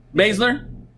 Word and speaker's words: Baszler: Next match, Alexa Bliss versus Shayna Baszler.